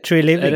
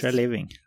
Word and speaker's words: Treliving: Hur uttalar man det? Du brukar vara så bra på det där. Nej, Treliving. – Det... – Ja, Treliving tror jag jag skulle sagt faktiskt. Treliving, ja ja.